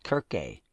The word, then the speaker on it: kirk: Comes from the old English and German word pronounced kirke. In Scotland, it was kirk.